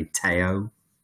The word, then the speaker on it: Tao: Tao is spelled T-A-O, but it's a Chinese word. So you don't say Tao, you say Tao.